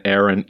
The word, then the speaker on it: Erin: To me, they both have a short E sound as the vowel. And if you reverse Erin, the boy's name, Erin, you get Nora, N-O-R-A.